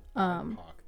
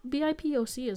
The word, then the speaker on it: BIPOC: Oh, BIPOC, that's how it's said. Okay. – Or BIPOC is also valid.